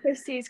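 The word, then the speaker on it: Khryssie's: With Chrissy's Creations. The pronunciation of my name is a little bit different. So it's spelled KHRYSSIES and then Creations.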